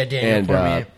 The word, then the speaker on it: Daniel Cormier: Yeah, Daniel Cormier.